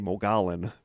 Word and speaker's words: Mogollon: I always don't know how to pronounce this, because it's M-O-G-O-L-L-O-N, so I always want to say Mogollon, but it's Mogollon.